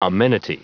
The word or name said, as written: amenity